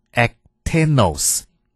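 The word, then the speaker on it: ectenos (ἐκτενῶς): 切切的这个希腊文读作 ectenos。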